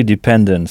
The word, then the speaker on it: dependent: I was totally dependent, c'est ça. Donc, en début de mot, on entend D, qui s'écrit pourtant D-E, dependent, avec E-N-T à la fin, et non A-N-T, comme en français.